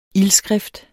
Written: ildskrift

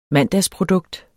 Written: mandagsprodukt

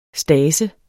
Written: stase

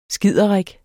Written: skiderik

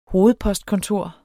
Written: hovedpostkontor

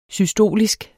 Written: systolisk

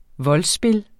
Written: voldspil

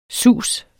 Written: sus

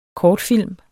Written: kortfilm

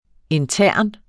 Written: intern

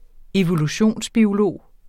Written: evolutionsbiolog

